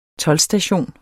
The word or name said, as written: toldstation